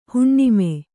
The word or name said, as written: ಹುಣ್ಣಮಿ